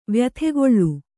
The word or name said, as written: ವ್ಯಥೆಗೊಳ್ಳು